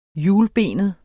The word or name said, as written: hjulbenet